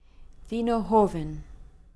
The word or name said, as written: Vino Joven